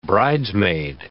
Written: bridesmaid

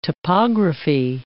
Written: topography

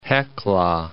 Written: Hecla

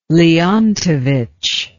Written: Leontovich

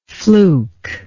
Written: flukeless

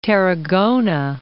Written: Tarragona

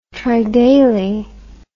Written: tridaily

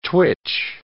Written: twitch

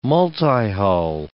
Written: multihull

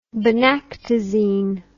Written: benactyzine

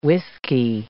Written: whisky

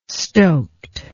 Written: stoked

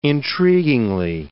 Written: intriguingly

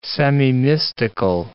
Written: semimystical

